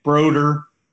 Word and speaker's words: Brodeur: I love that you pronounced Brodeur's name the same way I do, the French way. Yes. We don't let him try and like make it like a regular person's name, Brodeur. No, it's Brodeur.